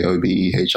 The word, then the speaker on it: Obehi: So it's like, when I'm writing my name down, I do actually spell it out, like, the proper way, Obehi.